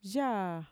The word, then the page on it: yaa: Mixtec